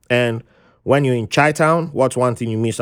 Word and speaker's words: Chi-town: Chi-town.